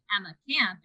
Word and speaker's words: Emma Camp: and I say Emma Camp, and she says, and that's spelled with three M's.